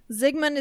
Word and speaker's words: Zygmunt: This is the story of Zygmunt Adamski. – Okay. – Zygmunt is the correct pronunciation.